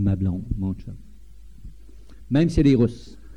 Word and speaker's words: chum: Et tous les hommes sont des chums. Ça s'écrit C-H-U-M, mais ça se prononce comme s'il y avait un T au début, un chum.